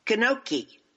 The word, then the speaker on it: gnocchi: Did I say that right? Gnocchi. The G is silent, right? Gnocchi. – Yeah, the G is silent. Yeah. Gnocchi is how they say it. – Oh. – You're doing pretty good, though. You knew the G was silent.